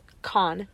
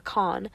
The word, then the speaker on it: Kahan: But I was sad because I did miss Noah Kahan. Or it's actually pronounced Khan. Noah Khan.